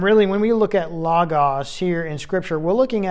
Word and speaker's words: logos: Now, the phrase the Word, okay, Word, W-O-R-D, Word comes from the Greek word logos, logos. And you've probably seen this many times, especially in Bible studies and such. It's spelled L-O-G-O-S.